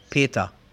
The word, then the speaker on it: Peter: El nombre Peter, ¿no? Peter, P-E-T-E-R. De hecho, en alemán lo pronunciamos Peta, con A, Peta.